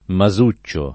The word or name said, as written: Masuccio